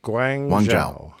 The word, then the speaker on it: Guangzhou: A Thai A330-300 was going from Guangzhou. I always say this wrong. – Guangzhou, China, to Bangkok. – Yeah, that's pretty good.